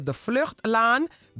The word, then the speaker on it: De Vluchtlaan: De Vluchtlaan, zeg ik het goed? – Ja, burgemeester De Vluchtlaan.